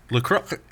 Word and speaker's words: La Croix: You know that's actually how you say it? – La Croix? – No, La Croix. Literally, really? It's French. La Croix. Yeah, it's like La Croix.